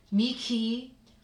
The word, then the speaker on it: mihi: Anima mea dominum qui potens est, quia fecit mihi, it's like a K, H, mihi mania.